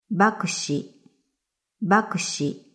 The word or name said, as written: Bakushi